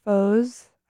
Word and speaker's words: Fauxes: This next song is uh, by local band Fauxes. I think that's how you say it F A U X E S.